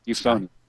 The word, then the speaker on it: Euphonium: Euphonium. – Euphonium.